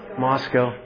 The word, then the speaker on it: Moscow: Moscow.